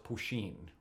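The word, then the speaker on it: poitín: But I had a rare opportunity a number of years ago to buy something called, it's spelled P-O-I-T-I-N. But I think it's pronounced Poisin or Poisin.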